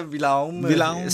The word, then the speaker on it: Willum: Willum? Ja. Er det ikke det? – Jo. – Willum Jensen. – Willum Jensen. – Nej, Alexander Willum, staves det, udtales. – Willum.